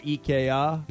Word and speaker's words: IKEA: So, IKEA, or what is it? IKEA. – IKEA. – Is that how they say it? IKEA? – Yeah, that's how they say it.